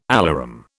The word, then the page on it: alarum: British English